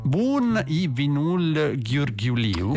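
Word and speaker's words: Bun e vinul ghiurghiuliu: Il titolo è Bun i Vinul Ghiurghiuliu. – Esattamente così, proprio. La pronuncia è questa.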